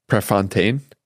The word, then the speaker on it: Prefontaine: It matters because of this quote by Steve Prefontaine.